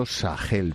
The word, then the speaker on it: Sahel: Ya que tienes el mapa, el inmenso Sahel, pronunciado con H gutural, es la zona del desierto del Sáhara al norte y de la sabana sudanesa al sur.